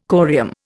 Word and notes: corium: Indian

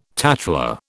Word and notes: tattler: British English

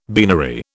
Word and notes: beanery: British English

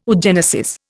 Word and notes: oogenesis: Indian

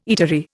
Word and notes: eatery: Indian

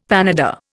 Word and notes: panada: Indian

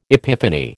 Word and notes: epiphany: American English

